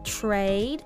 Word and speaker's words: trade: Trade in. Yes, sounds great. トレードのとではなく trade。 t の発音はあまり口を大きく開けなくても大丈夫です。